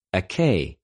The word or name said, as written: ἐκει